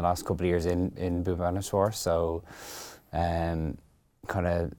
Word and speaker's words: Bhubaneswar: What do you know about Bhubaneswar? Am I pronouncing that right? – Almost, yeah. I think it's Bhubaneswar. – Bhubaneswar, yeah.